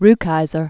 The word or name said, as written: Muriel Rukeyser